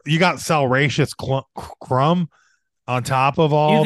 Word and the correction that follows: Salacious Crumb: Salacious. Repeat after me. Salacious. Say it. Salacious. – Salacious Crumb.